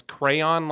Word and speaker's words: crayon: So on Wyatt's list, number 183 and 184, it's people who say, who pronounce crayon like crown, and they pronounce crayon like crayon.